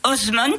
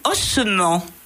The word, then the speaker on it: Osment: Alors, je vais prononcer d'une certaine façon cette femme qui arrive, le point de déséquilibre, Faiza Osmond. – Oui. – Mais Osment, O-S-M-E-N-T, moi je l'ai lu ossement, comme un tas d'os. C'est évidemment pur fantasme de ma petite tête de lectrice.